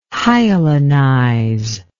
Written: hyalinize

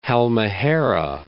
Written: Halmahera

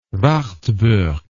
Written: Wartburg

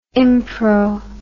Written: impro

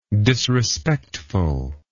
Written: disrespectfulness

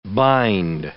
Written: bind

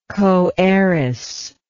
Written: coheiress

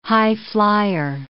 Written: highflyer

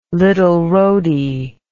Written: Little Rhody

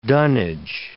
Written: dunnage

